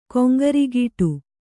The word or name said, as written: ಕೊಂಗರಿಗೀಟು